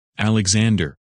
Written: Alexander

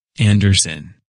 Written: Anderson

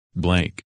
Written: Blake

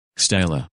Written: Stella

